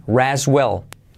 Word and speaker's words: rest: Rest y la T no se pronuncia. Rest well.